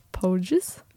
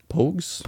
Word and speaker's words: Pogues: Og det passer jo bra, for vi skal jo høre neste låt, som er 'Fairytale of New York' av The Poges. Pogues, Pogues.